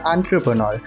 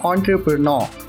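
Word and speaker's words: entrepreneur: एंट्रप्रनर और British British English पे use किया जाता है ऑन्ट्रप्रनर आर इज साइलेंट द एंड आर इज साइलेंट ऑन्ट्रप्रनर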